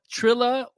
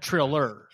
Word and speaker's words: Triller: I say Trilla. – Trilla. – Trilla. Trilla. – Trilla. – Trilla. – Don't be whiting it up with that Triller.